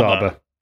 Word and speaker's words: ABBA: We got multiple emails that apparently we've all been saying it wrong. I kept saying ABBA last. It's ABBA. It's ABBA. Like ABBA. Abacus. Abacus. ABBA.